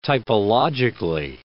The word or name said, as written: typologically